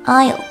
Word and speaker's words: isle: Isle，意为岛屿，虽然少个 a，但读音也是 isle 哦。